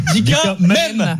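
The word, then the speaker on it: Dika Mem: M-E-M. M à la fin? – Oui, M-E-M. – Dika Men. Comme dans M&M's.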